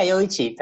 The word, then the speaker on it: Yoichi: Yeah, Yoichi, that's fine. Yoichi is good.